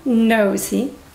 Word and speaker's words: nosy: Y cuidado con la pronunciación de esta también, que la O es O, nosy. Viene de nose, alguien que mete la nariz donde no le llaman, es nosy.